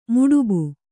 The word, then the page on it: ಮುಡುಬು: Kannada